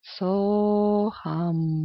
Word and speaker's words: So-Hum: На вдохе вы произносите как можно дольше «So», а на выдохе «Хам». Получается, вы настраиваетесь с вибрацией. «Со-хам». «Со-хам».